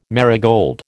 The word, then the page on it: marigold: American English